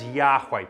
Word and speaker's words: Yahweh: God has a name, and his name is Yahweh. Previous generations pronounced it Jehovah. But it's a Y and a W, and it's two syllables, not three.